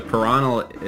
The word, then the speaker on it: Paranal: Yeah. So Paranal, it's Cerro Paranal, it's the mountain in northern Chile.